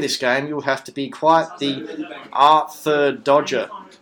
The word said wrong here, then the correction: Artful Dodger: Artful Dodger.